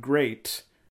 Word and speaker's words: GLEAT: I also will say, I believe the username is The Great Muda, because that it's, I believe it's a pun on the new promotion Great, which is spelled G-L-E-A-T. – Sure. Yeah, I mean, you know, ours can often be given that L pronunciation in Japanese, so that's just how it goes.